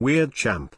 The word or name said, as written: weirdchamp